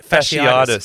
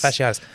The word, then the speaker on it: fasciitis: How do you say it? Plantar fasciitis. – Fasciitis. – Like fascist, but fasciitis.